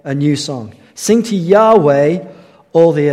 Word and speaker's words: Yahweh: When you pronounce it, it's pronounced something like Yahweh.